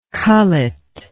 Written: cullet

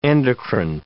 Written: endocrine

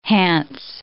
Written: Hanse

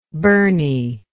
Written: Bernie

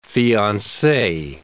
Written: fiance

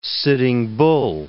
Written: Sitting Bull

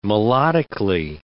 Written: melodically

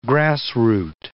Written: grassroot

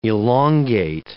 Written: elongate

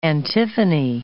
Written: antiphony

